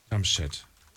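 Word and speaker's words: Jamshed: Ja, hi, hier ist der äh, Jumpchat aus sogar Nähe Mainz. Jumpchat? Genau. Ich weiß gar nicht, wie schreibt man das denn? Jam. Äh, e J-A-M-S-H-E-D. Jumpchat.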